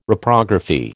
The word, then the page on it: Reprography: American English